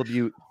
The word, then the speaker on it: LaBute: Neil LaBute, I feel like is how you say this. – Right. Neil LaBute.